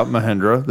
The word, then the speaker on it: Mahindra: Yeah, Mahindra.